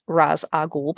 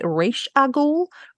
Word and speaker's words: Ra's al Ghul: So that's why most people, I would say, I'm going to go with saying that I think the majority would pronounce it this way, which is Ra's al Ghul, because that's the Arabic pronunciation. There is a slightly different interpretation, which makes it Ra'sh al Ghul, which is the Hebrew pronunciation.